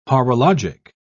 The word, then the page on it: horologic: British English